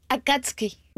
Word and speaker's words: Akatsuki: Akatsuki.